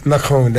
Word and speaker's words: Macron: ¿Cómo pronuncia Macron? Se nota que estudió muchos años y habla francés, porque lo pronuncia como los franceses, él. – Macron, hace una cosa.